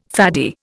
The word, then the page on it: faddy: Indian